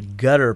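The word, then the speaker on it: Ghadar: There was a gutter party, pronounced just like the gutter on your house.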